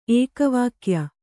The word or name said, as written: ಏಕವಾಕ್ಯ